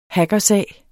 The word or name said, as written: hackersag